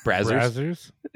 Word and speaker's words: Brazzers: I love how we always end up on like I just remember the episode where Victor brought up Brazzers. – Well, by the way, I finally did hear someone say that that's how it's pronounced. – Brazzers? – Yeah.